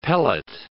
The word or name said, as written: pellet